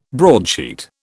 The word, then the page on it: broadsheet: British English